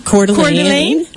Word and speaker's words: Coeur d'Alene: Coeur d'Alene.